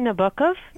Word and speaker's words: Nabokov: How do you pronounce Nabokov? – I would say Nabokov, but yeah.